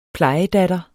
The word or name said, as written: plejedatter